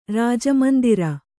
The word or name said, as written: ರಾಜಮಂದಿರ